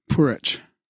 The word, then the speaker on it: Puharich: Andrea, Andrija, Andrea Puarich, Puharich, P U H A R I C H, P U H A R I C H, Purich.